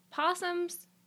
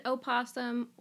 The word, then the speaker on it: opossum: You can pronounce it opossum, or you can pronounce it possum. It's been really common to pronounce it just possum for, like, the last hundreds of years.